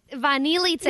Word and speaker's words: vanilice: That one goes to the person who tried to pronounce it and pronounced it wrong after I thought I pronounced it wrong. – Vanilice.